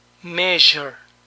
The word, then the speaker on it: measure: Este símbolo es más largo y representa el sonido sh, sh, sh. El mismo sonido que usan los argentinos para pronunciar la L en pollo, calle. Visión. Vision, vision, vision. Medida. Measure, measure, measure.